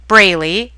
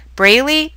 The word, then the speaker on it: Braley: Go to YouTube and look up my name, JoLynn Braley. It's spelled J-O capital L-Y-N-N, Braley, B-R-A-L-E-Y.